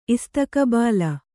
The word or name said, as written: ಇಸ್ತಕಬಾಲ